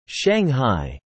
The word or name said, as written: Shanghai